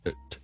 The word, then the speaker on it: at: 咁、那个发音咧，其实就系就咁 at，甚至 T 音都唔读，用一个所谓 glottal stop 嚟取代，就变成 at 咁样嘅。